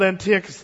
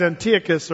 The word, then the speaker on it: Antiochus: Now we move then to Antiochus the Third, who is called Antiochus the Great. These guys, Antiochus or Antiochus, depending on how you want to pronounce that.